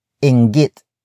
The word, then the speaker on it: inggit: And that is inggit. Inggit. Inggit. Inggit is spelled as I-N-G. G-I-T.